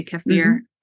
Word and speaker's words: kefir: And you can get that again if you're eating whole foods or if you're eating fermented foods such as sauerkraut, kimchi, kefir, or some say kefir, that's how I pronounce it.